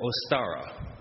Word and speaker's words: Ostara: or ostara, O S T A R A.